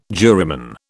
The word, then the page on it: juryman: British English